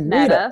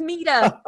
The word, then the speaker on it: Meta: A lot of people call it Meta. No, it's Mita. Mita. M-E-T-A. Mita.